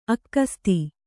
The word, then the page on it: ಅಕ್ಕಸ್ತಿ: akkasti